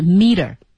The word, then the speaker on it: metre: 对，它读音还是 meter。 – meter。 – meter。但是大家注意哦，这个 T 呢有一个小小的浊化，会读成 d，所以是 meter。